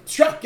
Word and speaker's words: Strzok: That's how you say his name? – Strzok, yeah.